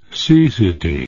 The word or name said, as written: cecity